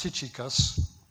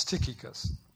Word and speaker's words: Tychicus: It's not pronounced Tychicus, it's Tychicus.